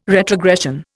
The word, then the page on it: retrogression: Indian